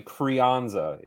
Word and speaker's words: Crianza: Yeah, I was thinking Roger Clemens, I think, but it's actually Crianza is the pronunciation.